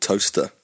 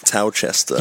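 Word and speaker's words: Towcester: Because it's T-O-W-C-E-S-T-R, is it? – Towchester. – Yeah, so I don't know if it's Towchester, so it's Toaster, that's simple. – Toaster's how it's pronounced.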